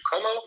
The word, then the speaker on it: Comeau: Como. My last name is spelled C-O-M-E-A-U.